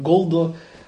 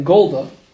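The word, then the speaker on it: golda (גולדא): It depends how you pronounce gold. He goes through it because they used to pronounce gold in some places in Europe with a comma under the gimel, like golda, or there could be a pronunciation like gulda. But if you pronounce it the way Shlomo and Aviv are pronouncing golda, so the proper way to spell is gimel vav lamed dalet aleph.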